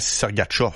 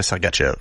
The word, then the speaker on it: Sergachev: Parce qu'il fallait dire aussi Mihal Sergachev. – Exact. – Mais tout le monde disait Sergachev.